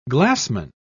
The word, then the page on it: glassman: British English